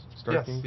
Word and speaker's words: Starkings: Starkings? – Yes, yeah, Starkings.